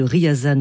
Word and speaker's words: Ryazan: Je rectifie donc la prononciation de la ville de Ryazan en Russie.